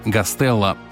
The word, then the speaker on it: Гастелло: Именно здесь его фамилию стали произносить на московский манер – Гастелло.